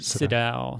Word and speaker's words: Sydow: Max von, how do you say his last name? Sydow? – I say Sydow.